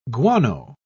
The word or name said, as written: guano